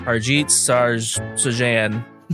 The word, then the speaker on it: Harjit Sajjan: In 2017, the Canadian Defense Minister, Harjit Sajjan, whatever. – Yes, it's very accurate. – Harjit Sajjan traveled to Greenland.